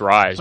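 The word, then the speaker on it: Ryse: Well, the first one is Reese. How do you pronounce it? It's the Crytek. – I think it's just Rise. I think it's pronounced Rise, just spelled wrong.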